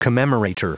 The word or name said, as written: commemorator